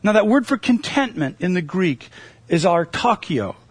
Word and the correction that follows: autarkia: autarkia. Let's say it right. Autarkia.